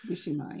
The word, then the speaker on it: Mishima: If I get the name right, Yukio Mishima. – Mishima, yes.